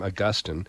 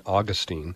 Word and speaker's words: Augustine: As Catholics, we say his name Augustine. And Protestants, I'd always heard his name as St. Augustine. But we're both talking about the same thing.